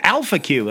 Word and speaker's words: Alpha Q: He must be going after Rodimus, who will lead him to Alpha Q, or Alpha Q, the stress on the first syllable.